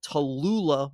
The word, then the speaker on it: Tallulah: Uh, this is um, I try to represent local whenever I can, and this is from the Tolu- Tallulah Brewing Company. I'm going to represent them, I should pronounce their name correctly.